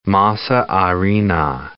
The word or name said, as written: masa harina